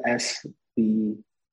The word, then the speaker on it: SBAR: S-B-A-R, is that right? – Yeah, S-B-A-R, S-bar.